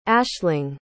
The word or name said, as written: ashling